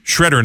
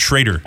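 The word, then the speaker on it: Shrader: It's an A with a thingy over it, but they say it's pronounced Shredder, not Schrader.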